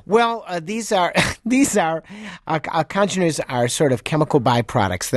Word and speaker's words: congeners: Well, Jim writes, I regret having to correct you, but when you were discussing hangovers, you were mispronouncing the word congeners. Coming from your upper Midwestern vocal cords, it sounded like codgeners. As in old codgers. – Like in old folks, that's right. – The N should be pronounced congeners, unless right.